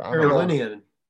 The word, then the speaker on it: Carolinian: Is that how you say it? What would it be? The Carolinian or whatever? – Carolinian. – Linean? – Yes. – Okay. – Like sorry. Linear. – Like linear.